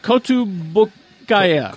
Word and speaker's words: Kotobukiya: Kotobukiya.